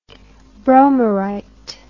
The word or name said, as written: bromyrite